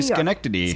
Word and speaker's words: Schenectady: How do you say this? Schenectady? – Hot Couture. – Schenectady? Is that what people say? – Oh, yeah. – Schenectady? – They say Schenectady.